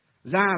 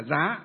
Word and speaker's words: gia: Gia là gì? Chữ giá, đọc là chữ giá. Chữ giá mình đọc là gia. À, hay là giá đều được cả.